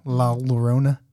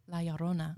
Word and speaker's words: La Llorona: La Llorona. Say it. – La Llorona. – La Llorona.